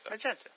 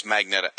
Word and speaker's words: Magenta: Anyway, you get one side with the original Trickster and Pied Piper and Heatwave and I can never say her name. Magenta. Is it Magenta? I guess it's just Magenta. – Magenta. – Oh, I always think it's Magneta.